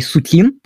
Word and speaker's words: Сутин: Это, кстати, по-своему не удивительно, просто потому что большинство членов правительства Колчака, как, например, человек с э, интересной фамилией Сутин, правильное ударение именно такое, Динс э, и так далее, многие из них были юристами.